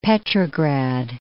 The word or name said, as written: Petrograd